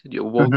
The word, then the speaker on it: Jovovich: Mila Jovovich, Jovovic, uh-huh. no sé cómo se pronuncia. – Jovovic.